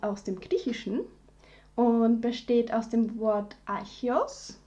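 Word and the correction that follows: Archaios (ἀρχαῖος): Ich nehme an, so spricht man es aus. – Archaios, fast.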